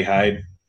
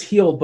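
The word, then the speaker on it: Hield: Like, it's really just Buddy Hyde and Fox. I have to make sure I correct myself to the correct pronunciation of Buddy Hyde. – You won't get a like. I believe it's Heald, but I just crossed the train tracks.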